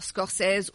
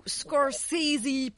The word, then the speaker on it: Scorsese: Et je parlais du film avec De Niro, bien sûr, réalisé par Scorsese ou Scorsese pour prononcer comme il se...